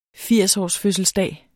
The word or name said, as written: firsårsfødselsdag